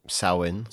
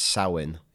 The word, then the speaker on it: Samhain: In Irish, you would pronounce it Samhain. – Yeah. – Like cow in.